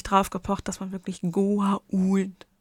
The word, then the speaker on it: Goa'uld: Und Beau Bridges zum Beispiel, der Schauspieler, der General Landry spielt, beharrte später in Staffel 9 und 10 darauf, wirklich die Aussprache Goa'uld zu sagen, weil er halt einen Freund hat, einen Schauspielerfreund, Elliot Gould.